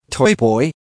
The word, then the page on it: toy boy: American English